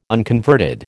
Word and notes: unconverted: American English

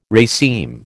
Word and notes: raceme: American English

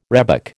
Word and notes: rhebok: American English

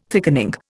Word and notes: thickening: Indian

